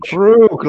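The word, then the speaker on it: Kruge: And I think that he was probably written in a way that allowed him to portray Crooge or Krug, however you say his name, in this particular fashion. – Ebenezer Scrooge. – Krug, like the champagne.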